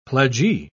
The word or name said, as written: pledgee